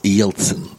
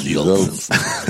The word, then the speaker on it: Jeltsin: Boris Jeltsin. Yes. Han ska uttalas på det. Så jag har nämligen läst ryska en gång i tiden så jag försöker använda det. Jättesnyggt ryska uttal. Ja, Boris Jeltsin.